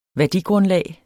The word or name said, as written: værdigrundlag